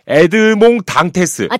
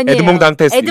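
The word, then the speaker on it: Edmond Dantès: 아 발음을 잘해야 돼요. 프랑스어입니다. 에드몽 당테스. – 아 단테스. 단테스 맞죠? – 프랑스어 전공 아니세요? – 아니에요. – 에드몽 당테스. – 에드몽 단테스. – 네.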